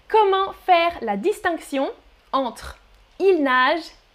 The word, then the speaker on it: ils nagent: But they are pronounced exactly the same. On prononce exactement de la même manière. Il nage and il nage, singulier, pluriel.